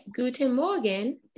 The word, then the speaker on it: Guten Morgen: Okay, Guten Morgen.